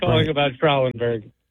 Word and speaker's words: Schrallenberg: It's pronounced Schrallenberg. I'm from a town called Dumont that used to be called Schrallenberg. – Okay, so we know. So we were correct, EJ. – I said it correctly. Is it Schrallenberg? – Yeah.